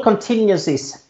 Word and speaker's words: Contingencies: Ja, alltså Civil Contingencies Act, det uttalas contingencies förresten, Ronny.